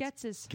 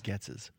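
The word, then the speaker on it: Goetze's: Getzes. – Getzes. – Yeah. G-O-E-T-Z-E. Getz. And it has an apostrophe S, so it Getzes. – Is Getzes.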